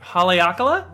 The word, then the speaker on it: Haleakala: It is from the Haleakala Distillery. – Haleakala? – Okay, thank you. I'm going to take your word for it. So here is their dark rum. Cheers. – Cheers. Yeah, Haleakala.